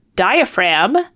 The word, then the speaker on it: diaphragm: Question 5: the word diaphragm has a silent G.